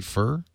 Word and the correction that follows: Foer: Furr? – And Joshua Four. Four. Yeah.